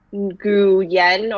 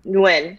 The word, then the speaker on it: Nguyen: And so instead of saying like Nguyen or whatever you would pronounce phonetically, you just say it Nguyen and that's it.